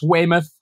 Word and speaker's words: Weymouth: It's Weymouth.